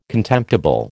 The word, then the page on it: contemptible: American English